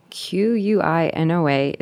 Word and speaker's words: quinoa: Q U I N O A. It's pronounced quinoa.